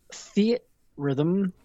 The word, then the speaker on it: Theatrhythm: I played a lot more Theatrhythm, which apparently is how you say it according Theatrhythm. – Theatrhythm, according to one of the producers who worked on the game.